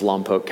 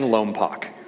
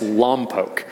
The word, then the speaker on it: Lompoc: I mentioned earlier I grew up a little north of here in the booming metropolis of Lompoc, California. And I can, by the way, that's how it's pronounced, okay? Stop saying Lompoc, all right? It's Lompoc.